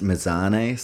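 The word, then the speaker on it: Mazzoni: Just quickly, 2 minutes left in this first round, and I think it's pronounced Mazzone, so I apologize if I've been getting her name wrong.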